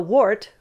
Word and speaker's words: WHART: Let's look at the first, because you attended a course, WHART, I don't know if that's how you pronounce it, W-H-A-R-T, Wildlife Human Attack Response Training. Whew, what was the purpose of this training? Whew. – Well, WART,